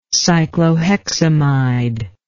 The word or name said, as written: cycloheximide